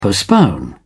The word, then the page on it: postpone: British English